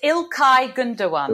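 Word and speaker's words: İlkay Gündoğan: It's Ilkay Gundogan. – Ilkay Gundogan. I know that G is a W.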